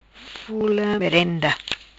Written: Fula Merenda

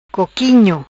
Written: Coquinho

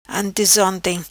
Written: Antezonte

